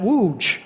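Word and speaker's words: Łódź: And you look at it, and I would say loads, but because there's a line through the L, there's a dash over the O, and there's a dash over the Z on the end, you pronounce that wooj.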